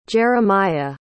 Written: jeramiah

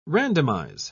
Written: randomize

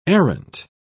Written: errant

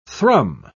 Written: thrum

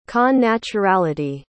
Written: connaturality

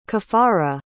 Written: caffara

